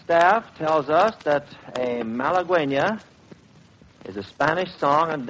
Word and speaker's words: Malagueña: I said malagueña. The announcing staff and the research staff and everybody else seem to be in all kinds of arguments about the pronunciation of Malagueña. But we've decided it's Malagueña,